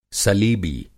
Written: सलीबी

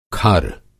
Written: खर

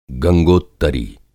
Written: गंगोत्तरी

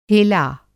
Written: हेला

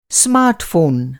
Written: स्मार्टफ़ोन

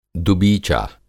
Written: दुबीचा

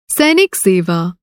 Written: सैनिक सेवा

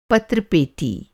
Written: पत्र पेटी

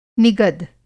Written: निगद

